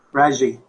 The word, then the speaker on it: Renjy: So I'm going to turn it back to Mark and Renjy. Is that the way you say your name? – It's Renjy. Renjy.